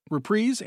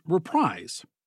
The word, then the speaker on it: Reprise: It can be pronounced two different ways, Reprise and Reprise. Sinatra always went with the Reprise, as in reprising a musical number or act.